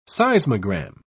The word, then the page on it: seismogram: British English